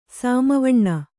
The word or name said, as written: ಸಾಮವಣ್ಣ